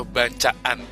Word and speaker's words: Bacaan: Jadi bacanya yang benar nih sebenarnya apa? Bacakan atau bacaan? – Bacaan.